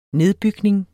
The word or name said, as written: nedbygning